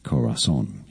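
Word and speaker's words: Corazón: In English, the pronunciation Corazon. You have to stress on that last syllable.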